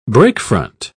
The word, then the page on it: breakfront: British English